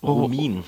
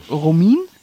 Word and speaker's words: Romijn: Wie heißt denn die, wie wird denn der Nachname ausgesprochen? – Romijn. – Romijn? Ja?